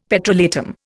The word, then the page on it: petrolatum: Indian